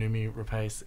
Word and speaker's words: Noomi Rapace: Both the actors that you've worked with in your past two features, Nicole Kidman and Noomi Rapace, am I pronouncing her last name? – You are.